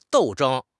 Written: 斗争

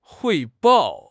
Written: 汇报

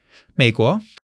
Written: Mei3 guo2

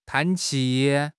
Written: 叹气

